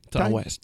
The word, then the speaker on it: Ty West: Comment on prononce ça? – Ty, je pense. – Ty West. – Ty West. – Yeah. – Non, Ty, c'est un Y. – Même si t'es texan, tu dis Ty West.